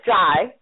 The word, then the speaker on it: Jai: Is it J-A-I? – Yeah, it's Jai. It's pronounced Jai.